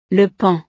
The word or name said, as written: le paon